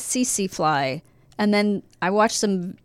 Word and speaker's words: tsetse: We are going to discuss how to survive an unfriendly insect, that insect being tsetse fly.